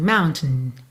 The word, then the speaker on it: mountain: Normalmente, quando você ouvir alguém falando a palavra mountain, essa segunda sílaba fica bem mais para dentro, assim, mountain, mountain. Tá? Muitas vezes do T já vai pro N, mountain.